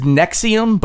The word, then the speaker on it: NXIVM: this is Nexium, but it's spelled N-X-I-V-M.